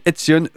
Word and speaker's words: Edsion: P-H-Y-S et Etsion E-D-S-I-O-N.